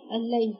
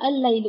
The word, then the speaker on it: الليل: أيضا في هنا خطأ شائع، يظن البعض أن كلمة الليل اللام فيها قمرية، يقول الليل، ما هي اللام هي بتطلق يعني. هذا ليس صحيح، لأن اللام التي تنطق هنا هي اللام التي من أصل الكلمة، هي كلمة ليل. أما اللام اللي هي للتعريف لا تنطق، الليل، وإلا كانت هتبقى إيه؟ الليل، لا ما كانتش هيبقى فيها لام زيادة، هنا شددت اللام فأصبحت الليل، وهنا اللام التي تنطق لام الكلمة وليست لام التعريف.